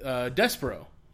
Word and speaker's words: Despero: Martian Manhunter, we go with Despero.